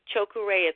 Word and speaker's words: Choku Rei: Just practice in the car and always breathing into your hara, making that connection, and then on the out-breath, Choku Rei, it's the vowel sound, so it's O, U, A, E, and you're going to hear it on the tape that I'm going to send you or the MP3.